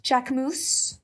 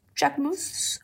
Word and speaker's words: Jacquemus: I think it depends how you wear it and how you style it, but Jacquemus. Jacquemus, I think I pronounced that properly.